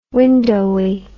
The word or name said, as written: windowy